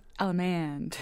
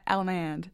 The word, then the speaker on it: allemande: It's usually pronounced allemande, right? With a little twang. I'm from North Carolina. Allemande.